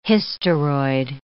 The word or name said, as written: hysteroid